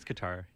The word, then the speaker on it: Qatar: Like, there's no going back for people from Carter or Qatar. I don't know how you say it. – I think it's Qatar, yeah. – Yes. – Qatar. – Qatar. – It's definitely not Qatar.